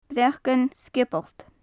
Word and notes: breacan sgiobalt: gaelic

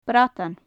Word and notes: bradan: gaelic

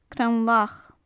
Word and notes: crann-lach: gaelic

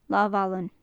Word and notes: lamhallan: gaelic

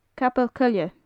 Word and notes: capall coille: gaelic

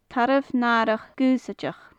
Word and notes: tarbh nathrach gaoisideach: gaelic

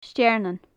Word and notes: stearnan: gaelic

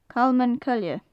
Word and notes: calman coille: gaelic

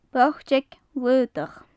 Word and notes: botag shlaodach: gaelic

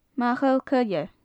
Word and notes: machall coille: gaelic

